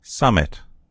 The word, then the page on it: summit: American English